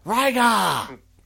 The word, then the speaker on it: Liger: Jushin Thunder Liger, everyone agrees. – Liger! – Or someone says we have to pronounce it Jushin Thunder Liger! Which is how it's done in Japan.